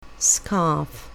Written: scarf

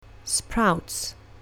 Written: sprouts

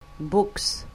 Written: books

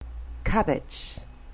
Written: cabbage

